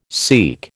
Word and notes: SEAQ: American English

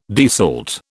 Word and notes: desalt: British English

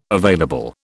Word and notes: available: British English

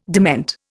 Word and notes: dement: Indian

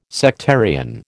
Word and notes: sectarian: American English

